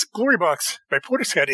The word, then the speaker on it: Portishead: And yes, it's pronounced Portishead. You can look it up.